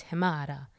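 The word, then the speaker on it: Himarë: So like Himare is spelt with an E, but it's Himara.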